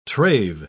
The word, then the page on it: trave: British English